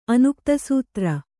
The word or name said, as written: ಅನುಕ್ತಸೂತ್ರ